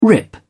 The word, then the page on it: rip: British English